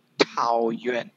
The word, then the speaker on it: Taoyuan: I'm looking at the map, and what's interesting is that Taiwan City, ta, ta, I'm ho- I hope I'm pronouncing it right, right? Taiwan um, is bigger than Taipei. – Taoyuan. – Taoyuan. Taoyuan.